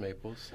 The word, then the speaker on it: Maples: My name's Thomas Maples. – Tommy Thunder. – Tommy Thunder. I was thinking when Matt mispronounced my name as Thomas Mayberry, I was thinking about going with that because I look a little like Opie, apparently.